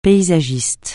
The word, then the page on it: paysagiste: francais